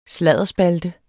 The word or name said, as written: sladderspalte